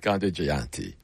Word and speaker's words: Jayanti: Gandhi Jayanti. Jayanti. I'll say it right. Gandhi Jayanti. There we go.